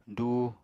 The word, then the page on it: duu: apatani